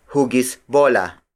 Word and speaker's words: hugis bola: Another variation is hugis bola. Hugis bola. Hugis bola. Hugis bola is spelled as H-U-G-I-S hyphen B-O-L-A.